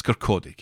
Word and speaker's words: Kirkcaldy: It's Kirkcaldy, Kev. Kirkcaldy. You don't pronounce the L.